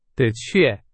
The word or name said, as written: 的确